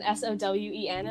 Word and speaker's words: Samhain: Because everyone said Samhain, and I like wrote it down Sowen,